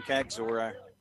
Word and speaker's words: Kaczor: I don't know why I keep thinking when I hear his name, Kagzor, I keep thinking of Star Trek. – Well, I've looked at it three or four ways. That's the only way to pronounce it, isn't it? K-A-C-Z-O-R?